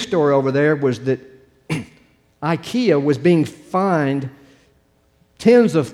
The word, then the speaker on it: IKEA: Here they y'all when this mispronounce it, it's called IKEA over here, but over there it was IKEA.